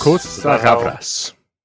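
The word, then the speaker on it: Costa-Gavras: Is that how? Costa Gavras? – Costa Gavras. – Yes, thank you, resident Greek on the podcast here pronouncing that.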